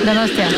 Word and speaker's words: Donostia: Donostia.